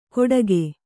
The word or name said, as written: ಕೊಡಕೆ